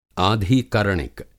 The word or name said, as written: आधिकरणिक